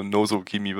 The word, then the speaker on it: Nozo Kimi: Und wir kommen zum dritten Anime in dieser Runde und zwar ist das Nozo X Kimi, also Nozo Kimi wird da, glaube ich, ausgesprochen.